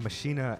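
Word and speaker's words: Maschine: He makes all of his beats on a machina. Is that right? Correct pronunciation. A machina MK3.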